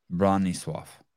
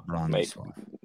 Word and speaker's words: Bronisław: And when I hear you say it, is there an F at the end? Bronisław? – Yes, Bronisław. – Okay, but the last letter is a W. – W, yeah. – But you pronounce it like an F. – Yeah, in Polish, it sounds like Bronisław.